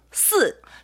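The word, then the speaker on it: 스 (四): 숫자 4의 발음은 정확하게 어떻게 되죠? – 스. – 쓰죠.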